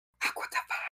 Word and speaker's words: Aqua Tofana: Okay, well, first, if you're going to say it, you need to say it right. And it's Aqua Tafana. You got to say it like that, in that way.